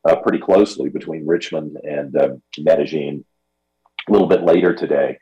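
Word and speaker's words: Medellín: I mean, I grew up calling this Medellin. I mean, am I crazy? But what I was told on Friday is correct. It's Medellin. This is the way the locals say it here in the country of Colombia, in South America.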